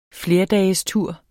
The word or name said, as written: flerdagestur